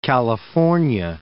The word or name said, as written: California